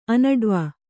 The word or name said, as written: ಅನಡ್ವ